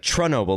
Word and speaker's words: Chernobyl: It's more like Chernobyl. Like, it's pronounced slightly different because it's Russian. It's CH, but I think it's a little different.